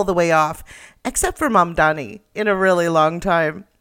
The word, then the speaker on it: Mamdani: And furthermore, the name is Mamdani. M-A-M-D-A-N-I. You should learn how to say it.